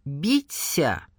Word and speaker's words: Бися: Считается, что возле озера, в роще, на лужайках обитает некое жуткое существо — Бися, с ударением на «я».